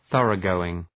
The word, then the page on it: thoroughgoing: ‘ɵʌrə,gəʋıŋ